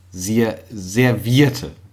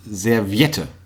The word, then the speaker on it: Serviette: Es heißt ja Servierte. – Ja. – Also im Grunde Serviette. – Eigentlich heißt es Serviette. – Genau. Serviette.